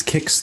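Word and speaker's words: KICS: That's KIX, that's spelled with a K-I-C-S, right? – Yeah, correct.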